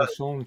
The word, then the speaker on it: Assange: Also äh, Pressefreiheit, Meinungsfreiheit, ähm, da muss man ja nur ähm, Julian Assange sehen. – Mhm. Ähm, ich hoffe, das war jetzt richtig ausgesprochen. – Julian äh, Assange, ja, war so mit.